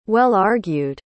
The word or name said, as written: well-argued